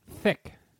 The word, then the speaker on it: Dsic: Thick. Didn't I say you put your tongue against your teeth? – Yes, thick. A soft palate.